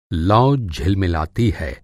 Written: झिलमिलाना

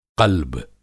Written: क़ल्ब

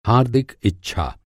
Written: हार्दिक

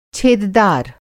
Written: छेददार

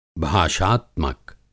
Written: भाषात्मक

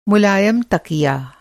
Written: मुलायम

